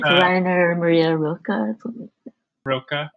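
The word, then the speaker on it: Rainer Maria Rilke: I'm going to butcher this, but Rainier Maria Rilke.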